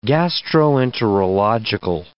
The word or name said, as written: gastroenterological